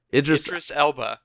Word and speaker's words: Idris Elba: Idris Elba.